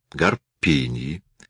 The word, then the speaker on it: Гарпиньи: «О, да, работы Гарпиньи. С ударением на «пи». Гарпиньи.